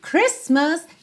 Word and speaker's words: Christmas: Voltando então, agora nós já sabemos que Christmas não tem a letra T pronunciada, ok?